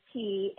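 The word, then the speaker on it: Ky: My first name, my nickname is Key, and it's spelled K-Y like the jelly.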